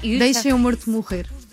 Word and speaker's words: Deixa-me Morto Morrer: There is a track called Deixa-me Morto Morrer. Am I pronouncing that? Am I butchering that pronunciation? – It's in Portuguese, it's very hard to pronounce Portuguese, but it's almost perfect.